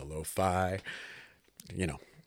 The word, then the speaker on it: LoPhi: So I go by the artist name Lo-Fi, but it's spelled L-O-P-H-I. It's my tongue in cheek. I'm always this kind of guy. Wordplay is my thing, but my name Phil is in that.